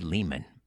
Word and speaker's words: Lehman: First up, Ari Lehman. I'm told it rhymes with demon, so it's Ari Lehman.